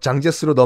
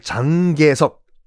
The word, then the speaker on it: 장제스: 요거 나중에 뭐 중, 중국사 편을 혹시 하게 되면은 이 순원에서 장계석, 장제스로 넘어가는 권력 과도기 자세히 설명드릴게요. 아주 자연스러운 권력 승계가 아니라 권력 강탈이었습니다. 하여간. 그니까 지금은 중국 국민당을 장계석, 중국 발음 장제스 가 이끌고 있어요.